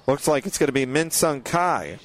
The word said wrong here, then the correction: Ki: Actually, they're going to say it's Min Sung Ki. So the long E sound.